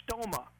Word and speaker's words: stoma: Now the word mouth is the Greek word stoma, S-T-O-M-A. We would probably pronounce it in English stoma.